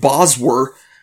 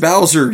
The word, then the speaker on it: Bowser: it's some guy named Boswer. – Boswer? – Yeah. No, it's pronounced Bowser, you fucks.